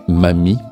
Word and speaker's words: Mammy: Vous avez entendu dans ce dernier bloc la pièce Mizu no Naka no Imitsu du groupe japonais Mami, qui s'écrit M-A-M-M-Y.